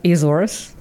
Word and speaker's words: Azores: Azores.